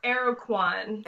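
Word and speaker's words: Aracuan: But I think it's pronounced Arucan. – Arucan. – Yes. – Yeah, that is.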